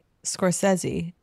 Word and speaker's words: Scorsese: Apparently, Scorsese, and yes, I said it the correct way. Yes, that is the pronunciation.